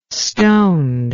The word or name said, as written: stoned